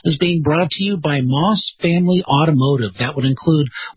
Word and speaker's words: Maus: It's Moss is spelled, of course, M-A-U-S.